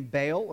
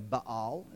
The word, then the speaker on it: Baal: Uh, you remember the name Baal or Baal, if you want to pronounce it correctly. But we don't. I don't want to. I'm in the South. I'll say Baal.